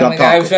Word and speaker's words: Dontaku: That's how you say it. Dontaku. I've been mispronouncing it all weekend.